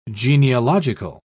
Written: genealogical

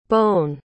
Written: Bowne

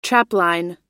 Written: trapline

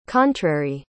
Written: contrary

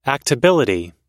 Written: actability

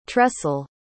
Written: tressel

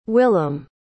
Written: willam

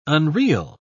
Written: unreal

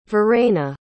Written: verena

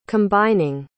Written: combining

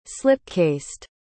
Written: slipcased

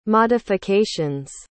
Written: modifications

